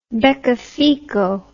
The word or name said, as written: beccafico